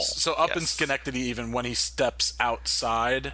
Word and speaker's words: Schenectady: all the way when he's up in Schenectady, right? Am I saying that right now? – Whatever. Well, it's Schenectady. The actual city yeah. – is Schenectady. – Yes, Schenectady. Yes.